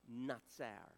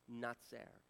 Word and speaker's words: Natsar: The word Nazareth comes from a Hebrew word that basically is pronounced, I want to make sure I get this right, Natsar. Natsar.